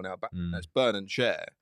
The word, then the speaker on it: Schär: It's pronounced Schär, just for punditry.